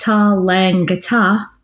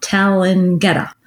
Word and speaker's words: Tallangatta: Georgina Devine said, FYI, that Tallangatta is pronounced Tallangatta, not Tallangatta.